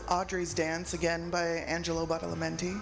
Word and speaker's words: Badalamenti: Badalamenti.